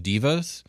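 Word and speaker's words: deva: So first we're going to talk about the astral devas or the devas. Or the correct pronunciation is divas.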